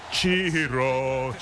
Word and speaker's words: Ciro: You actually pronounce it Ciro.